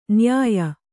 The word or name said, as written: ನ್ಯಾಯ